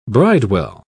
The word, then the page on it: bridewell: British English